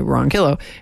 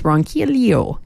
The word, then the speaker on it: Ronquillo: It's not Ronquillo, not like Spanish. It's Ronquillo. Quillo. So you kind of do the Spanish thing. And then you also then kind of, yeah, just saying. Then you pronounce the L at the end.